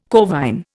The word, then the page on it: corvine: Indian